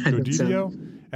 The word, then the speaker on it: Jodidio: Jodidio, is that how you say it? Thanks. – yeah, Jodidio.